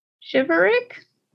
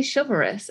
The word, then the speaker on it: chivalric: Chivalric? – Spell it. – C-H-I-V-A-L-R-I-C. – Chivalrous? – It does not say chivalrous. It has an ick on the end. – Hold on. – It's like chivalric. Not chivalrous.